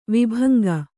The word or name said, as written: ವಿಭಂಗ